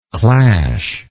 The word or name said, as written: clashingly